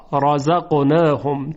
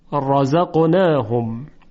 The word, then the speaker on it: رزقناهم: در کلمه رزقناهم را، رزقناهم، چون حرف را فتحه داره تفخیم میشه را، یعنی هیچ وقت نمیگیم رزقناهم، ترقیق نمی کنیم، اون رو با صدای درشت تلفظ میکنیم، رزقناهم.